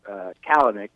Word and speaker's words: Kalanick: Travis Kalanick is the pronunciation.